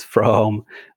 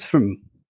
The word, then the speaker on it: from: And um, so, for example, the word for is not pronounced as for very often, but we pronounce it as for. – Yeah. – Or the word from is not pronounced as from, but pronounced as from. – From.